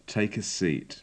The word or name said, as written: take a seat